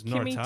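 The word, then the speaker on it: Noritara (乗れたら): Uh, Kimito Nami ni Noritagara. I said that wrong. It's Noritara. Kimito nami ni Noritara.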